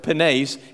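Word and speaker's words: penes (πένης): The first word is penes, P-E-N-E-S, and that second E is a long E. It's pronounced penes.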